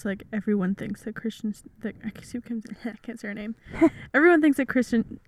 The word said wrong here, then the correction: Kristen: Kristen Stewart.